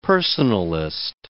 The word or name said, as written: personalist